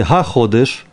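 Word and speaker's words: הַחֹדֶשׁ: А если га ходыш, то это будет месяц какой-то конкретный, да? Это гей читается как га. Там голосовка обычно будет э, ставить потах. Га ходыш.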